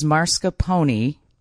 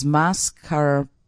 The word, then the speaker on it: mascarpone: They say the wrong way to say it is mascarpone. The correct way to say it is mascarpone.